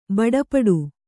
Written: ಬಡಪಟ್ಟು

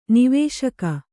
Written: ನಿವಾಸಕ

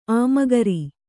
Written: ಆಮಗರಿ

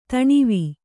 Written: ಧನ್ವ

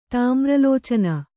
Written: ತಾಮ್ರಲೋಚನ

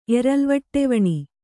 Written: ಎರಲ್ವಟ್ಟೆವಣಿ

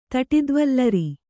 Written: ತಟಿದ್ವಲ್ಲರಿ

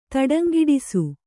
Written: ತಡಂಗಿಡಿಸು

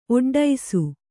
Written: ಒಡ್ಡೈಸು

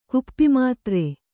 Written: ಕುಪ್ಪಿಮಾತ್ರೆ

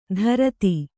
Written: ಧೃತಿ

